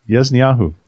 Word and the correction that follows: Ya'azaniyahu: Each man It's Ya'azaniyahu. It's a kind of a difficult name, Ya'azaniyahu.